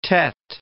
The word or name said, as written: Tet